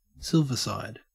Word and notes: silverside: Australian English

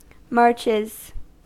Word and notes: marches: American English

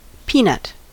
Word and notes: peanut: American English

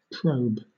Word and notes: probe: British English

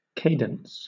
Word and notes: cadence: British English

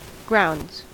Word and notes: grounds: American English

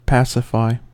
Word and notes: pacify: American English

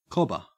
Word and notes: cobber: Australian English

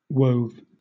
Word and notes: wove: British English

